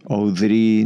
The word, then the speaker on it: Oudri: Oudri. Okay. Oudri in Spanish, so you can choose.